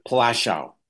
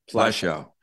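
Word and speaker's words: Płaszów: Plaszow, is that how you say it? – Plaszow. – Plaszow.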